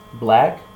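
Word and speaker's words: 6LACK: But another song that I think is sticking out this week is OTW by Khalid, Ty Dolla $ign, and 6 Lakh. – Black? It's pronounced black.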